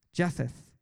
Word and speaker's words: Japheth: And Noah had three sons, Shem, Ham, and Japheth. Japheth. Am I saying that right, Jacob? Absolutely right.